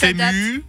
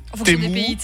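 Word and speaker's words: Temu: Et alors, déjà, tu dis Timou, toi ? – Ouais, ouais, je sais. – Toi, tu dis comment ? Bah, je sais pas. Je crois qu'il y a plusieurs prononciations. – Il y en a qui disent Tému, Témou. – Ouais, Témou.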